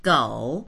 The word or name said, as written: gou3